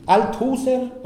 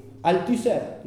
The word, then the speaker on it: Althusser: Sí, Althusser, pronunciado a la alemana, pues un apellido. Es judío con un apellido alemán, seguramente era de Alsacia. Louis Althusser, sí. Althusser, dicen los franceses, que afrancesan todo.